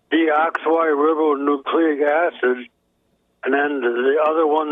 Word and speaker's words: deoxyribonucleic: It's pronounced deoxyribonucleic acid.